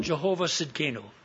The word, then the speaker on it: Jehovah Tsidkenu: This is called Jehovah Tsidkenu. The T is silent.